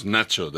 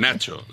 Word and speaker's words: Nacho: You must use Nacho. That is my nickname, and it's very more convenient. Nacho, like nachos.